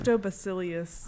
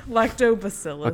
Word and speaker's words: Lactobacillus: Lactobacillus. – Oh my gosh, I was joking, but you nailed it. – Lactobacillus. – Okay, say all that. – Lactobacillus bulgaricus bacillus.